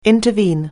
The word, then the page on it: intervene: anglais